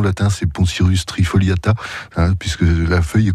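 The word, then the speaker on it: Poncirus: Alors, est-ce que je prononce bien le poncyrus ? – Le poncyrus, tout à fait.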